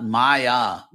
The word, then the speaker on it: Maya: This is not Maya with the long A, which means illusion. This is Maya, the short A, which is the uh, that uh, sort of mystic architect and builder uh, of the Asuras, Maya.